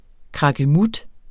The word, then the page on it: krakkemut: kʁɑgəˈmud